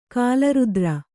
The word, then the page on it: ಕಾಲರಾತ್ರ: kālarātra